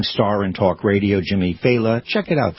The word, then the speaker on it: Failla: His name is pronounced Faila. Faila. It's spelled F-A-I-L-L-A. So people go, Faya, Faila. You know, but okay. It's Faila.